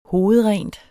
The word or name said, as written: hovedrent